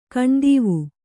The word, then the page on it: ಕಣ್ದೀವು: kaṇdīvu